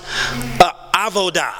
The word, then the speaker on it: avodah: It's pronounced avodah. Can we say that together at a count of three? One, two, three. Avodah. I may not even be saying it right, but amen. But I'll spell it out for you so you can go and study it. It's A-V-O-D-A-H.